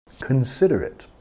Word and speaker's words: considerate: Considerate. Considerado. Igual a thoughtful. Ooh, he's very considerate. She's very considerate. Okay? Piensa mucho en los demás. Considerate. Vamos a enfatizar la segunda sílaba. Considerate.